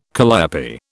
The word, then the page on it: calliope: British English